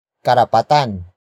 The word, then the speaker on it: karapatan: And that noun is karapatan. Karapatan. Karapatan. Karapatan is spelled as K-A-R-A-P-A-T-A-N.